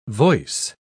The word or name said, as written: voice